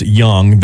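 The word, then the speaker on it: Jung: Well, before you do that, I was going to say, if it was spelled George Young with a J, J-U-N-G, pronounced Young, that was the American back in the 70s that essentially helped Pablo Escobar introduce cocaine to America, to the West Coast.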